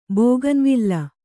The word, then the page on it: ಬೋಗನ್‍ವಿಲ್ಲ: bōganvilla